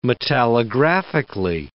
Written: metallographically

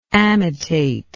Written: amitate

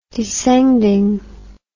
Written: descending

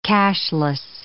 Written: cashless

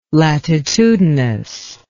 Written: latitudinous

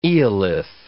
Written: eolith

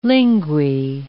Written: linguae

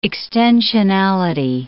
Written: extensionality

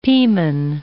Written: Piman

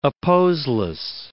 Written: opposeless

